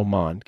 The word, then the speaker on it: Mond: So it's Tua, Fromm, Mound, Bryant, Mound. Oh, Mond. – Kellen Mond. I mispronounced that.